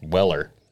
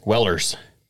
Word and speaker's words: Weller: Yeah, but there's no S, and it's not possessive either. It's just Weller. – People like to pronounce it otherwise. – Wellers.